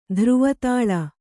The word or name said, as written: ಧ್ರುವತಾಳ